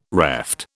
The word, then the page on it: reft: British English